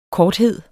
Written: korthed

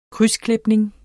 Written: krydsklipning

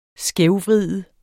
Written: skævvride

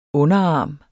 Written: underarm

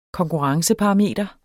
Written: konkurrenceparameter

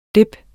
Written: DIPPENE

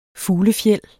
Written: fuglefjeld

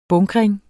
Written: bunkring

